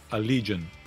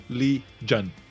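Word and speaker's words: Allegaeon: Bom, escreve Allegaion, mas pronuncia Allegion. Eu acho. eu, não, eu procurei aqui no Twitter dos caras. Aí eles falam. Boa. Pô, todo mundo pergunta isso pra gente, mas a pronúncia, a pronúncia é Allegion.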